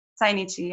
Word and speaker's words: Zainichi: Like, is it Zainichi? – Zainichi, yeah.